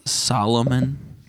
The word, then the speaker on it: Solomon: Wasn't Solomon the king in the Bible? King Solomon? – Oh, he was wise. – Salmon. – Yeah. – I think it's Solomon, but yeah.